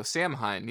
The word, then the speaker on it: Samhain: Has a daughter named Halloween, and his son is named, I'm going to say it literally how I'm seeing it, Samhain? I'm not sure to pronounce that. – Oh, Samhain, yeah.